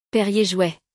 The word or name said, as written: Perrier-Jouët